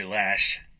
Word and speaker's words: Loesch: and losing talent like NRA spokeswoman Dana Loesch. I'm sorry, Lash. It's spelled Loesch.